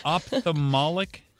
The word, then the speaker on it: ophthalmic: You are an ophthalmic technician. Did I say that correctly? – Yes, you did. – Ophthalmology. Not to be confused with ophthalmology. Guys? There's an H in there.